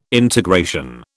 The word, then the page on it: integration: British English